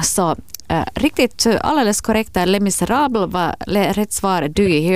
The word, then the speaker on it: Les Misérables: Les Misérables. – Det var fantastiskt uttal. Mycket bra. Les Misérables.